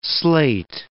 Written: slate

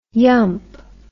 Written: yump

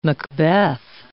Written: Macbeth

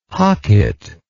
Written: hocket

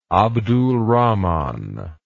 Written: abdulrahman

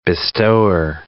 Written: bestower

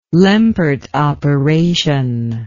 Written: Lempert operation

